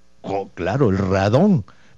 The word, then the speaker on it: radón: Radón, RADDON, acento en la O, radón.